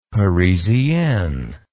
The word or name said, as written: parisienne